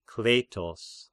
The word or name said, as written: Kleitos